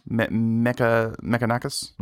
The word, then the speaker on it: Mechanicus: Anyway, Warhammer 40K Mechanus? How do you pronounce that word? – Mechanicus? – Mechanicus? Sure.